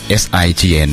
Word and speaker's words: Sign: วันไหนใจมันเหงายืมหัวใจไปได้ไหมมีเพลงดังในตำนานเลยนะครับเพลงนี้ในยุคของ 2540 ไปปลายกับเพลงนี้นะครับนะฮะวงเอาเดี๋ยวเขาตั้งชื่อใหม่ว่า Sign Out นะครับ SIGN เนาะอ่านว่า Sign เนาะ